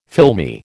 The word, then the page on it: filmy: American English